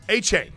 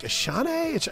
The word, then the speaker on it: Achane: Oh, Achane, the running back from A and M. I'm like, Ashane, and it's like, no, it's like it's spelled, dude.